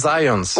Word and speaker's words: Zajonc: J'ai-tu pas dit Zajonc? – Ben, j'avais dit plus Zajonc, mais... – Ah, parce que j'ai prononcé un petit peu à l'anglais. J'ai prononcé mon polonais à l'anglais.